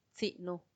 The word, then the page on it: tsinu: Macuiltianguis